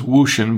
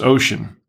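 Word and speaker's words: Wouxun: It looks like it says Wooshin, but it's pronounced Ocean in Chinese.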